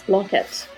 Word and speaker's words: Blanquette: On to today's topic, the Blanquette de Veau. Did I say that right? – Yes, Blanquette, yeah.